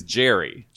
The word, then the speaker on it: Gerry: It's spelled G-E-R-R-Y. – It's Jerry. – Jerry. – It's a soft G.